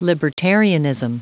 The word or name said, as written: libertarianism